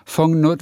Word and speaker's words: vong: Fong, Fong her. Fong, wie schreibt man das? – V-O-N-G, Punkt, Punkt, Punkt und dann her. Fong, Fong Nutzung her.